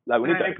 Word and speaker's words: Lagunitas: Lagunitas? – Lagunitas, yes.